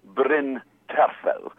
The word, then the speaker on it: Bryn Terfel: And also, of course, I know that Bryn Terfel, I never quite know how to say his Welsh name. I'm going to say Bryn Terfel. Wonderful. – Correct.